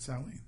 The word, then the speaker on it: Samhain: No, well, that's how you pronounce it, Samhain.